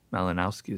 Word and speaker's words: Malinowski: And what happened, this is just not my theory, it's the theory of the Malinowski is how I pronounce it,